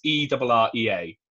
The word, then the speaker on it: Errea: It was made by the company, is it Aria? I can never really know how to say their name. It's Errea.